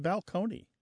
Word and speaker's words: balcony: My favorite change that I love to talk about when Martha and I go and do live events in the rows is the word balcony, place on a stage that you can look down upon the seats. Used to be pronounced balcony.